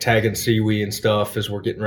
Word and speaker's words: SEWE: SEWE, we pronounce it down here in the lowlands.